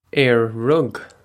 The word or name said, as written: Ar rug